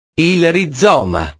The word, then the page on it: il rizoma: italiano